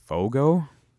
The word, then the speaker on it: Fogo: Fogo. – Fogel, I think that's how it's pronounced. F O G O.